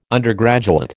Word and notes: undergraduate: American English

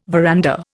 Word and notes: veranda: Indian

